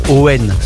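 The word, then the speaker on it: Eoghan: Ça s'écrit Eogan, mais apparemment, ça se passe Owen, son prénom. Donc ce serait Owen Masterson.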